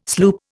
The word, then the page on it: sloop: Indian